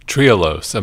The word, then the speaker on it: trehalose: Trehalose.